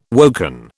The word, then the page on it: woken: British English